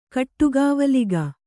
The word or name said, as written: ಕಟ್ಟುಗಾವಲಿಗ